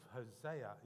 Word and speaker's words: Hosea: I love the message that we see in the book of Hosea. Is that how we pronounce his name? H-O-S-E-A. Hosea? Yep, okay.